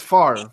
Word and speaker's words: Favre: It's spelled Favre, but it's Favre.